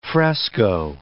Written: fresco

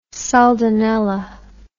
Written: soldanella

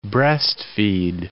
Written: breast-feed

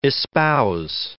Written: espouse

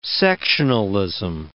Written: sectionalism

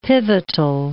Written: pivotal

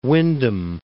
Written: Windham

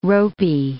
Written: ropy

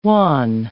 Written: wan

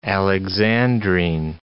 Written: alexandrine

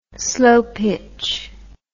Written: slopitch

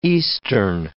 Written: eastern

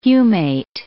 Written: humate

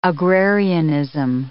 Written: agrarianism

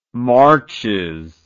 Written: marches

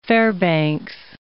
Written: Fairbanks

